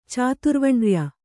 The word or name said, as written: ಚಾತುರ್ವಣ್ರ್ಯ